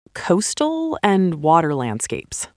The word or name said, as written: Coastal & water landscapes